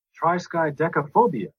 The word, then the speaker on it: Triskaidekaphobia: Triskaidekaphobia. – There he is. There's my friend. – So, actually Stephen King. – It is. Listen. – Triskaidekaphobia.